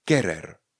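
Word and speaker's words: querer: Další slovo třeba je kerer. Kerer. Píšeme Q-U-E-R-E-R. E kerer.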